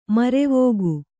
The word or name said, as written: ಮುರವಿಕ್ಕು